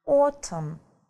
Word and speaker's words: autumn: Так вот, осень на английском языке будет autumn. Осень. Autumn. Да? Посмотрите, сколько много букв, а читается всего лишь 4 звука.